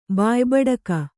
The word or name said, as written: ಬಾಯಿಬೂಟಕ